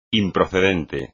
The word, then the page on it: improcedente: espagnol